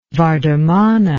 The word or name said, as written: vardhamana